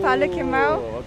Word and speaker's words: Palo Quemao: Tomorrow I will go to Palo Quemao. Palo Quemao. Okay. That's the yes. Right pronunciation. – Yes. Yes. It's good. It's good.